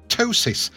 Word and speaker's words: ptosis: Gabrielle on Boomerang Brunch with Rise, and this is the lady who suffered terrible bullying at school because of a condition called ptosis. That's got a silent P on the front of it, which even when it was treated, left her with a flickering eyelid.